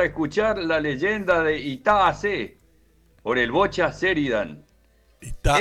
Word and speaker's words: Hase: Asé, que se escribe H-A-S-E. Ahí la H suena como J. Por eso es Itá Asé. La H en guaraní es sonora, no es como el, como en el español, que la H es muda.